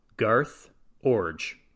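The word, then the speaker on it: Garth Iorg: I have to say, I had no idea how you were going to pronounce that. I intentionally did not research the pronunciation of this player's name. It's spelled I-O-R-G. And of all of the pronunciations, I'm not sure I even imagined that that was going to be the correct one.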